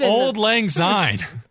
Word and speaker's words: Auld Lang Syne: Auld Lang Syne? How do I do that? Oh my God. That's the most unsportsmanlike moment here. – What's wrong with my pronunciation? – It's Auld Lang Syne.